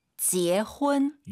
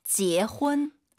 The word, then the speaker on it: 结婚: 지혜훈. – UN 사이에 어 들어가야 됩니다. 지혜훈. 한 번 더 들어볼게요. – 지혜훈.